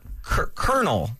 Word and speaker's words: colonel: Colonel. That's how you would say, like Colonel Sanders. – Are those words pronounced the same way? – Yes. Colonel is with a K. Colonel is with a C. – Yeah. – It's pronounced the exact same way.